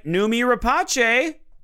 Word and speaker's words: Noomi Rapace: Also, I looked at the pronunciation. It's Noomi Rapace. – Oh, it's Noomi Rapace. – Mm-hmm. – Not Rapace. No. Noomi Rapace.